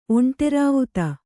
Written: ಒಂಟೆರಾವುತ

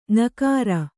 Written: ಞಕಾರ

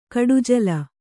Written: ಕೊಡೆಜಾಲಿ

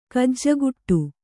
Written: ಗಿಜಿಗುಟ್ಟು

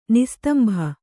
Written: ನಿಸ್ತಂಭ

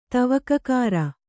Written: ತವಕಕಾಱ

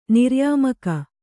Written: ನಿರ್ಯಾಮಕ

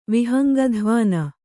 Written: ವಿಹಂಗಧ್ವಾನ

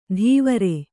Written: ಧೀವರೆ

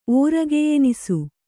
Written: ಓರಗೆಯೆನಿಸು

